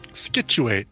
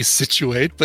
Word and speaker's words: Scituate: Scituate, sure. – What a weird town name. – That is. Could be a silent C, could be Situate, but that seems even weirder.